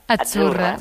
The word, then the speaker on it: Azzurra: La Z no es la Z como es decimos en catalán tan sonora, no. Ah, es Azzurra. – Muy bonito, muy bonito. – Es como pizza.